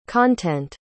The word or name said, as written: content